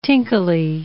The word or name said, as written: tinkly